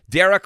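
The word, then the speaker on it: Darragh: And by the way, Dara Cross is spelled D-A-R-R-A-G-H. D-A-R-R-A-G-H.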